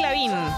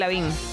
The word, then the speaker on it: Lavigne: Toda la vida le dije así. – no sé, la gente nunca me lo corrigió hoy. Es la, Abril Lavín. Me lo corrieron los oyentes. – Salvo que haya una pronunciación que no estamos conociendo y que vos la supiste ver. Yo creo que es Abril Lavín.